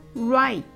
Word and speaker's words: right: right はライトではなく r ライトと発音してください。